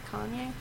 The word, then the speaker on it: Kanye: Yeah, it says K-A-Y-N-E. That's not Kanye. – Oh, it's K-A-N-Y-E. Isn't it Kanye? – Yeah, Kanye West.